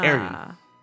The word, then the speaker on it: Arjen: Arion is spelled A-Y-R-E-O-N, right? – Mm-hmm. – His name is A-R-J-E-N. He's Dutch. – Ah. – Arion.